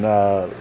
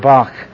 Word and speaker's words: Bach: This is a PH sound, like in physician, P-H-Y. This is a key sound, like in German, Bach, Bach. Don't often hear his name pronounced that way, but that is correct, Bach.